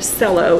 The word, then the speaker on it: cello: So as she gave her report, I did not have the heart to correct her pronunciation of C-E-L-L-O. She said cello instead of cello.